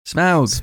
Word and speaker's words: Smaug: Desolation of Smaug! Is the theme tune... – Smaug. – Oh, don't. Do that, sir. Don't do that. What do we all say? Smaug or smog? – Smaug.